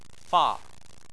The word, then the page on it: fa: 4